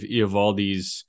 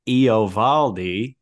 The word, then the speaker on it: Eovaldi: I watched the highlights of Eovaldi's major league debut with Vin Scully calling it.